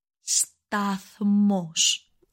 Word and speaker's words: σταθμός: So station in Greek is ο σταθμός. – It's really difficult to pronounce. So it's ο σταθμός. – I know it's a pretty difficult word to pronounce, so we're going to break it down.